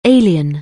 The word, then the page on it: alien: English